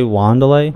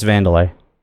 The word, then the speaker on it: Wanderlei: Wait, did you say Wanderlei? – Wanderlei. – Yeah, no, it's Vandalay. It's... – Really? But it's spelled that way. – Yeah. It's spelled with a W. It's Portuguese, so...